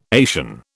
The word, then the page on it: Asian: British English